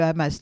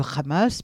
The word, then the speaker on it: Hamas: non pas le H comme il se prononce en français, comme... le plus souvent, il est muet, voire légèrement aspiré, mais le prononcer à la manière israélienne en insistant sur le « r » du Hamas.